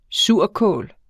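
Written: SURKÅL

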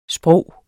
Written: sprog

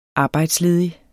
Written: arbejdsledig

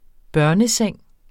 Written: barneseng

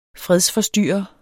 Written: fredsforstyrrer

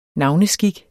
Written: navneskik

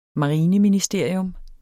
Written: marineministerium

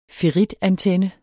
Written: ferritantenne

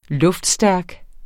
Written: luftstærk

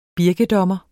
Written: birkedommer

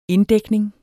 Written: inddækning

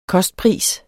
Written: kostpris

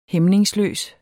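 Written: HÆMNINGSLØST

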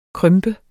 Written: krympe

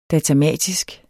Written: datamatisk

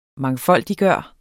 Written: MANGFOLDIGGJORTE

